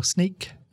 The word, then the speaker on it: Snyk: Just look for Sneak, which is spelt SNYK.